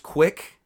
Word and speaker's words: CWC: CWC is pronounced quick for anybody out there who's unnoted.